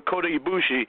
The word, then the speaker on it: Kota Ibushi: So you would like to see them surprise you with Kota Ibushi coming on to WWE Raw? – Definitely.